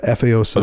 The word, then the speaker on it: Efeosa: They named me Efeosa Mabutimangbo, and you have to say it like that or it doesn't make sense. But Efeosa. – Otherwise, you're saying headdress.